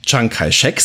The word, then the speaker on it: Chiang Kai-shek: Wobei die Republik China unter äh, Zhang Jiechi, bitte erschießt mich nicht, mein Chinesisch ist nicht gut, das war völlig falsch ausgesprochen. Im Deutschen würde man auch Chiang Kai-shek sagen.